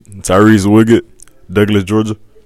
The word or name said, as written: Tyrese Woodgett